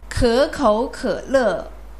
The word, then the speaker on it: 可口可乐: 可口可乐.